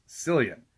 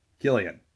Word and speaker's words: Cillian: There are a lot of people that seem unfamiliar with Irish naming conventions, and they want to pronounce his name as Cillian. It is Cillian. Just because it has a C does not mean it has that soft S sound to it.